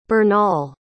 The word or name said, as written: bernal